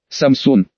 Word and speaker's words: Samsung: Корейцы говорят Samsung, что звучит довольно своеобразно на слух для любого, кто хоть раз сталкивался с ветхозаветными преданиями и тамошними героями.